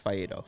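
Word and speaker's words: Faedo: It was I think it's Alex Fiedo. – It is.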